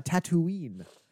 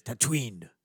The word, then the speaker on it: Tatooine: But that reminds me, he was born on Tatooine. But in the Boba Fett premiere, he says, Tatooine. He's in the sands of Tatooine. – He smushed it together. – He does. – He's skipping a syllable.